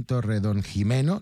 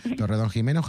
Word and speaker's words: Torredonjimeno: María Elena Higueruelo nació en Torredonjimeno, lo he dicho bien, ¿verdad, María Elena? – Sí. – Torredonjimeno, Jaén.